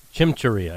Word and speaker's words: chimichurri: I don't know if I'm pronouncing this right. Chimichurri steak. Am I pronouncing that correctly? – Chimichurri, I believe.